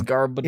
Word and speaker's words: Garbodor: It's Garbodor. – It's garbage and odor.